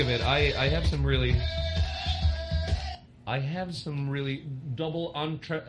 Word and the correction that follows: entendre: How do you say it? Double entendre? – Entendre. – Double entendres.